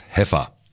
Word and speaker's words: heifer: And someone has very texted in, and I knew I'd pronounce this wrongly, actually, afterwards, but just to say that a heifer, I said the cow is a heifer, and it's spelt like that, but it's pronounced heifer.